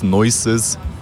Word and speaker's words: Noixxxez: Genau, ausgesprochen, also geschrieben, wie du es gesagt hast, N-O-I-X-X-X-E-Z. Ausgesprochen, glaube ich, Noises, wie Geräusch auf Englisch.